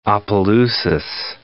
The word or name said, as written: Opelousas